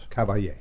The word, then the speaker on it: Caballé: Caballé.